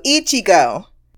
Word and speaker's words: Ichigo: Ishiago, I want to say that every time, and I know now that it's Ishio. I'm still saying it wrong. Hold on, let me get my pronunciation book out. There we go. Ichigo. There is nothing that lends itself to a nickname with that type of first name.